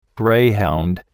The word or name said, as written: greyhound